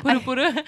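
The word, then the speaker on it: Puru puru (ぷるぷる): Or how did you say it? I messed it up. – Puru puru? Yeah. Puru puru is bouncy skin. – Oh, I messed it up. – How do you spell that? – P-U-R-U. And then P-U-R-U. – Puru-puru.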